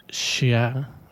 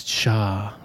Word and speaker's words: Chaix: That was Nicolas Chiaix, I think you say that, C-H-A-I-X. Not that good on the French pronunciations. Someone told me a long time ago, you just don't say the last couple of letters and you get away with it. Nicolas Chiaix, there we go.